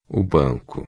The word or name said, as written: banco